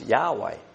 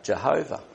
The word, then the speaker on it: Yahweh: And most people who study Hebrew say this probably should be pronounced Yahweh rather than the older Jehovah.